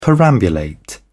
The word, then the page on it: perambulate: British English